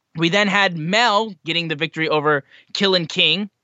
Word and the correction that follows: Kilynn King: I believe I pronounced, or was it Killian King? Did I pronounce that right? It is Kilynn King.